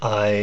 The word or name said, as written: ai